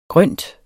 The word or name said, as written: GRYNT